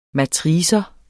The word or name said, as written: matrix